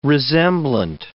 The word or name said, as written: resemblant